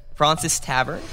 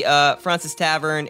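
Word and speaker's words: Fraunces: B, Francis Tavern in Fida. I may be saying that wrong. It's like Francis, but with extra U.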